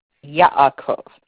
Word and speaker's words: Yaakov: Yaakov. Y-A apostrophe A-K-O-V.